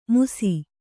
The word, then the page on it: ಮುಸಿ: musi